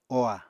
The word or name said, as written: oha